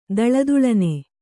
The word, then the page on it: ಥಳಥಳನೆ: thaḷa thaḷane